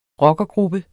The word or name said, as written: rockergruppe